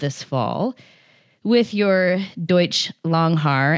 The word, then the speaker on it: Langhaar: And am I pronouncing that right, Langhaar? – You are, yes. I think okay, the Germans may say it a little bit differently, but I don't have their accent, so.